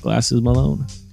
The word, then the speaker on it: Glasses Malone: Did I pronounce that right, Eric? Glasses Malone? – I believe so. – Yes, it is.